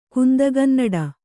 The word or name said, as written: ಕುಂದಗನ್ನಡ